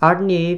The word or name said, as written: Ardnave